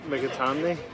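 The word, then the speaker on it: McAtamney: Yeah, so it's Jude McItamney. So if you break it down into like four parts and just pronounce it like McItamney and say it once, that's it.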